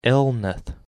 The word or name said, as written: Elnath